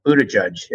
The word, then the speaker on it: Buttigieg: Young mayor, Buttigieg, as you told me, his name is pronounced, who's the mayor of South Bend.